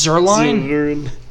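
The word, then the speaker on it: Zuerlein: So, Greg Zerlin. – You couldn't pronounce Zerline? Zerlin.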